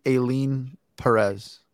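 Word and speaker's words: Ailín: A-I-L-I-N. Maybe Aileen? Aileen? – Alien. – Alien Perez. Yeah, man. – Aileen Perez.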